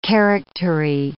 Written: charactery

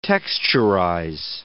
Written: texturize

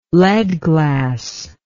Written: lead glass

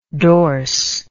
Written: dorse

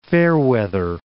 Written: Fairweather Mountain

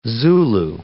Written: Zulu

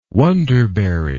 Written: wonderberry

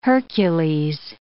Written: Hercules

